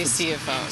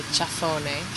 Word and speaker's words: Ciafone: We say Ciafone. – Ciafone. And when you're in Latin America? – I say Ciafone.